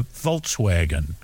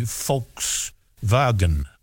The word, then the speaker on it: Volkswagen: A Volkswagen, it could be Volkswagen. – If you pronounce the W as the Germans do, as a V.